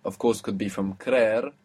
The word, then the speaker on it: creer: So, to believe is creer, creer, and it has two E's, CREER, creer.